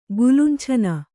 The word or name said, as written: ಗುಲುಂಛನ